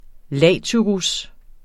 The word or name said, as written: latyrus